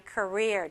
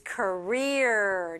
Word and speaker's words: career: Because I wanted to change my career. – 자, 직업을 바꾸고 싶어서. 근데 한국 분들도 커리어 이렇게 하죠. – But pronunciation for native speakers is career. – 네, 이렇게 돼야 돼요. Career.